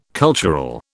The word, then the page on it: cultural: British English